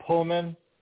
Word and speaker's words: Pullman: Pullman, Sonya? Did I get that right that time? – Yeah.